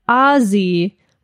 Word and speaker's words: Aussie: You pronounce it Aussie with a hard Z.